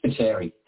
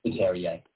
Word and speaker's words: Spiteri: Spiteri. – That's what I thought. – Spiteri, yeah.